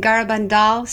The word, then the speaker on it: Garabandal: So the apparitions happened in Garabandal, Spain, from 1961 to 1965, despite how Mark pronounced it three times just now.